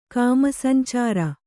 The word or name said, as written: ಕಾಮಸಂಚಾರ